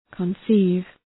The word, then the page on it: conceive: {kən’si:v}